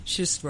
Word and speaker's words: schist: Schist rock. That word. What is it again? – It's schist. S-C-H-I-S-T. – Schist. – Yeah. Imagine that you're drunk. You'll be able to pronounce it correctly.